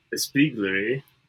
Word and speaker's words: espiègle: I'm between esque and espiègle. – Okay. The first two, one, espiègle? – Espiègle, yeah.